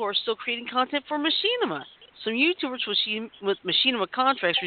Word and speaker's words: Machinima: It's pronounced yes. – Machinima. – Machinima. – You've been stressing machine. It's machine.